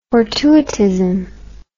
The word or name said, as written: fortuitist